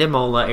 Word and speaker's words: Imola: So they move back to Forli and Imola area, not to be confused with the Ebola area. – It does sound like Ebola. – Yeah, that's kind of how I remember how to say it.